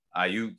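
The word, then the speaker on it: Aiyuk: You know, I think he was kind of overshadowed by Brandon how do you say his name? Iyaku? – Brandon Ayuk? – Ayuk, yeah, last year.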